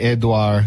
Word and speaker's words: Edouard: This is like Edouard, if it's in French, you don't even pronounce the D. Edouard, who makes these stupid names for tropical storms? – I don't know.